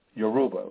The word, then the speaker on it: Yoruba: Yoruba. Yoruba is spelled Y-O-R-U-B-A.